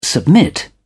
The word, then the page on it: submit: British English